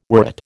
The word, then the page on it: worrit: American English